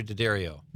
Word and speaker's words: Daddario: Alexandra Daddario.